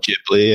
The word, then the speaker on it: Ghibli: I don't know how you say it. But Ghibli, yeah.